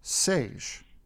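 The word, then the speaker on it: Säje: The group, by the way, is called Sage. Rhymes with beige.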